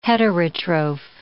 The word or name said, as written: heterotroph